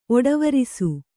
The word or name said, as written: ಒಡವೆರಸು